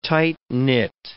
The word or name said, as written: tight-knit